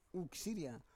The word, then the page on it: uk siria: Roon